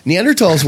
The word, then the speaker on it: Neanderthals: Neanderthals, which is how you say it correctly, Neanderthals were actually...